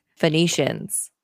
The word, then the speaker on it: Phoenicians: Hey, it's Phoenicians, like the word phonetic. Like the irony of me saying Phoenicians wrong when you would sound it out phonetically.